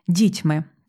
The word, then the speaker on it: дітьми: Насправді, правильний наголос буде «дітьми». Так, «дітьми» – це правильно, але тут в поезії маємо «дітьми» і маємо теж пасивний дієприкметник – забутий.